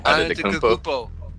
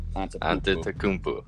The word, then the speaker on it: Antetokounmpo: Antetokounmpo. Yep, Antetokounmpo.